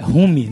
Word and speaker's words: Rumi: Ao Rumi, acho que é assim que pronuncia o nome. – É, Rumi, né? É. Rumi. É, R-U-M-I. – A-L-R-U-M-I.